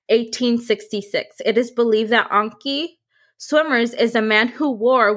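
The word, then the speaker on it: Antjie Somers: Ankie is spelled A-N-T-J-I-E and Swimmers, S-O-M-E-R-S. So it was like my brain wanted to say Ankie Sommers, but I looked it up and I think I got the pronunciation correct.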